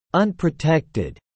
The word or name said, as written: unprotected